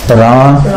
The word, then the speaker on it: Rama: So then you have to see the long A. Rama.